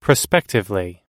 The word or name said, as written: prospectively